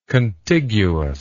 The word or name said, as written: contiguousness